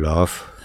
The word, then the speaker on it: loft: Yo vivo en un loft. Ah. ¿Lo he dicho bien? – No se ha notado la T, pero sí la F, supongo ah, que la habrás dicho bien, sí.